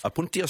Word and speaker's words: Opuntia: We're talking about, I'm going to say it wrong, opuntia? Cacti. – Yes. – No? Yes? – Apuntia stricta.